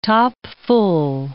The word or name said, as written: topfull